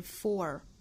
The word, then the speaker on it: for: 好，我们再看后面半句， for you and for me and the entire human race FOR 这个词卷舌成 FOR 那它是弱读的，所以我们也把它发成 FOR 不是 FOR，这个词要重读的话是 FOR，但是我们说到虚词在句子里一般弱读，所以这个词在这里应该读成 FOR， FOR YOU AND FOR ME AND THE ENTIRE HUMAN RACE。